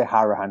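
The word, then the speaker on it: Hourihane: Whereas with Connor, I think I've said this before, you know, Connor, I say Harahan because that's how you pronounce his name.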